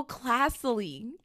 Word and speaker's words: classily: Like casify, right? Like class, C-L-A-S-S-I. – Classily. – L-Y. – Class silly. Classily. – Like class silly, like S-I-L-L-Y, silly.